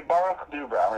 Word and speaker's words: Boruch DuBrow: I'm afraid I'm gonna butcher it as it is here, but I have Rabbi Boruch DuBrow. How do you say that? – That was good. Rabbi Boruch DuBrow, yeah.